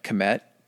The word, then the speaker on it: Kemet: So it was actually known as the Black Land, and what the word, they're not sure of the pronunciation of it, but what it was like, Kemet, K-E-M-E-T.